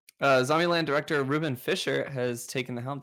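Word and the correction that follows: Fleischer: Did I pronounce that right? Flesher.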